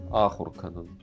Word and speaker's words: Ахурканан: Ахурканан, тоже слитно произносим. То есть в слове Ахурканан у нас ударение на первую А.